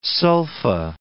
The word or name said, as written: sulfa